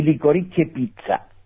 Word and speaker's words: Licorice Pizza: Y Licorice Pizza.